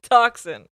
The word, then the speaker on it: Dachshund: It's toxin. – Oh, then why do they spell it like that?